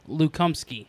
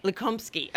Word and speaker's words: Lukomsky: It is Lukomsky. – Lukomsky. Okay. – And this is how he told me to pronounce it. So for everyone, because I did an interview with him. – Oh, good. – It's like you're asking your friend Lou to come ski.